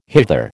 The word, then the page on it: hither: American English